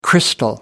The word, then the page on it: crystal: American English